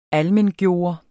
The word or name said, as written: ALMENGJORT